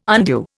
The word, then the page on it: undue: Indian